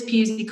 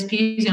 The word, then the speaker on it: Pusey: I'm Liz Pusey Comms on Instagram, and I'm Liz Pusey on LinkedIn.